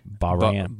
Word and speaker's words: Bahrain: Bahrain. Bahrain, I think. – Yeah. – Yeah, I put the emphasis on the ba. Or else it sounds like I'm saying brain.